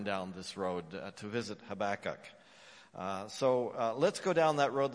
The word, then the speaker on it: Habakkuk: And as I said, we're going to Habakkuk. I think you got to say it with me. Let's get it. Habakkuk. Let's do it again. Habakkuk. Ha-ba-kuk. Habakkuk.